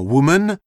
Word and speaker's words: woman: Le O dans woman se prononce ou, comme dans le français, coup, en plus bref.